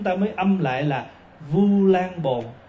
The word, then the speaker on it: Vu Lan Bồn: Đúng ra là Ulambana hay là Ulambồn, mà nhưng mà mình đọc trại ra thành Vu. Chữ Vu bây giờ là thế chữ ô đó, U Lam, thì mình đọc là Vu Lan Bồn.